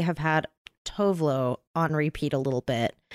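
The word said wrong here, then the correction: Tove Lo: Someone told me that it's pronounced Tuvlu. Because she's Swedish, right? – Tuvlu.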